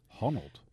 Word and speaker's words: Honnold: I wanted to mention this guy, Alex Honnold, I'm going to say his name is, which is his name. – Honnold? – Yeah.